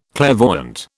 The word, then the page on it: clairvoyant: British English